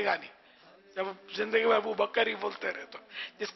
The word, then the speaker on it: ابوبکر: اور ابو بکر یہ پہلے بھی میں کافی سمجھا چکا ہوں کہ یہ تلفظ غلط ہے. ابو بکر نہیں ہے, ابو بکر کاف ساکن ہے.